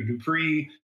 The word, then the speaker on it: DuPré: It's DuPré, and everybody pronounces it Dupré or Dupré, but it's Dupré. There's a little accent over the E.